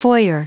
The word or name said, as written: foyer